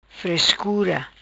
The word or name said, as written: Frescura